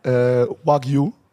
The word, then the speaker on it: Wagyu: Und dann hatten wir Wagyu, haben wir uns bestellt. Hab ich es richtig ausgesprochen? – Ja, sehr gut.